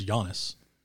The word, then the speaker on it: Giannis: Everybody calls him Giannis. – Well, even then, it's Giannis.